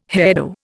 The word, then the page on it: hairdo: Indian